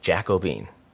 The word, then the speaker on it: Jacobin: So this is how you say it, Jacobin.